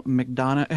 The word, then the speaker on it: McDonough: Falcon Rising is an action movie that's directed by Ernie Barbaresh, and it co-stars Neil McDonough.